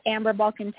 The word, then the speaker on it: Balcaen: And you guys can follow me on Instagram, Twitter, at AmberBalcaen 10 on Facebook is at AmberBalcaen Racing. My last name is spelled B-A-L-C-A-E-N.